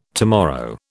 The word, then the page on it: tomorrow: British English